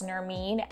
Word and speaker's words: Nermin: it's Nermeen,